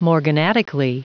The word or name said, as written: morganatically